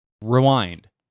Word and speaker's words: rewind: I put the emphasis on wind. – Rewind. Emphasis on the second syllable.